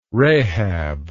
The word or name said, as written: rahab